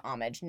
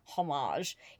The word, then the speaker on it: homage: Which PSA for everyone wondering, the original correct pronunciation of that word is homage, not homage. It's not like du fromage of cheese.